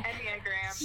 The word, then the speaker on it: Enneagram: Is it Enneagram or Enneagram? Okay. – Enneagram. – Yes, I got it right.